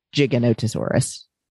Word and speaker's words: Giganotosaurus: And it's actually pronounced in the paleontological community Giganotosaurus.